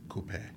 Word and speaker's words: coupe: Well, first of all, you're saying it wrong. – Okay. – Coupe. It is coupe.